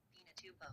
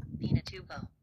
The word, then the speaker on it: Pinatubo: Pinatubo. – How did you say that? – Pinatubo.